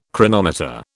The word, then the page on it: chronometer: British English